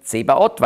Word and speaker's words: Zebaoth: Zava ist die Armee auf Hebräisch, Zvaoth und dann griechisch ausgesprochen Zebaoth, weil das Neue Testament griechisch ist, oder ein hebräisches Wort, griechisch ausgesprochen.